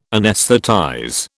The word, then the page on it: anaesthetize: British English